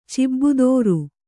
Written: ಚಿಬ್ಬುದೋರು

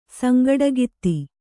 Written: ಸಂಗಡಗಿತ್ತಿ

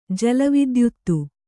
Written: ಜಲವಿದ್ಯುತ್ತು

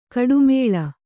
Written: ಕೊಟ್ಟೆಮುಳ್ಳು

